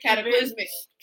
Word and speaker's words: cataclysmic: How do you say it? Catechismic, Cataclysmic. – Cataclysmic.